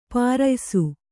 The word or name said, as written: ಪ್ರಾಯಶಃ